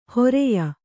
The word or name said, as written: ಹರೆಯ